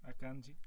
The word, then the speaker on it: Akanji: mas acho que é Akanji. – Akanji.